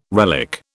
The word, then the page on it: relic: British English